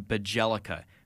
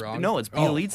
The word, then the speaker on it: Bjelica: So I've been to a ton of Kings games, and my cousin, who's a massive Kings fan, we always call him Bajelica, because that is exactly how he plays. He just kind of, like, flails. But is that how you say his name? – No, it's Bjelica. Oh, okay.